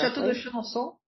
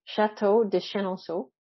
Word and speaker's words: Chenonceau: and I went to Château Chenonceau. I don't know if I pronounce that correctly. – Château de Chenonceau, oui. – Château de Chenonceau.